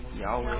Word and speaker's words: Yahweh: Y-H-W-H. What are the two possible pronunciations? There are two possible pronunciations. – Yahweh. – I told you yesterday scholars argue about which is correct. – Yahweh and Jehovah.